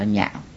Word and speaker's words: nhạo: Chính chữ nho viết là chữ lạc, cùng viết một chữ, nhưng khi nào nói về cái vui ấy, thì đọc là lạc, mà khi nào nói là ưa thích ấy, thì đọc là nhạo.